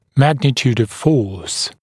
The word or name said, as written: magnitude of force